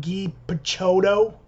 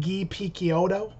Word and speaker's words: Guy Picciotto: I guess MacKay is the right way to say it, just like I've been saying Guy Picciotto or something like that, where I guess it's like Guy Picciotto.